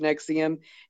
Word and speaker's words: NXIVM: It's spelled NXIVM like Roman numerals, but yeah. It's pronounced Nexium.